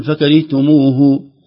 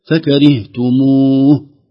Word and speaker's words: فكرهتموه: لو وقفنا فكرهتموه، أي حرف نقف عليه يكون عليه سكون، سواء عليه فتحة ضمة كسرة، ما ننطق الحركة عند الوقف، ما نقول فكرهتموه، أنت ما تقول بسم الله الرحمن الرحيم، لا الرحيم هكذا، الحرف الآخر نسكنه يكون ساكن ليس فيه حركة، فكرهتموه.